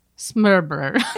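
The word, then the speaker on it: smørrebrød: Like, there's a word for open-faced sandwich, and it's spelled S-M-O-R-R-E-B-R-O-D. But you pronounce it like this. Smurbrer. – Oh, no. – Smurbrer. Smurbrer. Smurbrer. Like, you almost don't say any sounds. – Wait, spell it again. – S-M-O-R-R-E-B-R-O-D.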